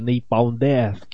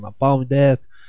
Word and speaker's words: Napalm Death: chegamos à conclusão que a pronúncia correta é Napalm Death e não Napalm Death.